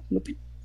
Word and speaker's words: Lupin: Eh, de las recientes acabo de ver, se pronuncia Lupin, ¿será? Lupin. – no está en Netflix. Sí. Lupin. – Ah, es, francés, cierto, Lupin.